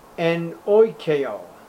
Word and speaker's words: enoikeo: Enoikeo, E-N-O-I-K-E-O, enoikeo.